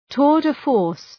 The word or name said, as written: tour de force